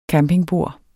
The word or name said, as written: campingbord